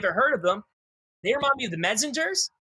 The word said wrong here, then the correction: Menzingers: and they also, I didn't pronounce all right, the Menzingers. – There you go.